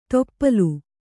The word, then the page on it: ತೊಪ್ಪಲು: toppalu